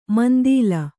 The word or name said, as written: ಮಂದೀಲ